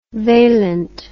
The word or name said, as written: valent